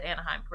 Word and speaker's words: Anaheim: So they get a room at the downtown Hilton in LA for the first night, and then they went to Anaheim. Did I say that? I think I said that right. – They go to Disney? Yes, you said Anaheim correctly.